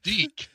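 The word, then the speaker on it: DiC: Hey, it's made by Dick. A Viacom company. – Pronounced Deek.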